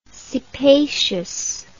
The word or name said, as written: cephaceous